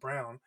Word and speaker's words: Braun: They traded Christian Brown, B-R-A-U-N, pronounced Brown.